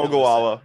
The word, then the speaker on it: Ogallala: To the Ogallala. Yep. Ogallal. I don't know how to pronounce it, but what I do know Ogallala. Yeah, you got it the first time.